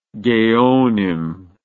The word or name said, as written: geonim